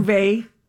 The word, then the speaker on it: Beauvais: Beauvais from Beauvais, Beverly Hills.